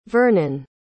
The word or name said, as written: vernon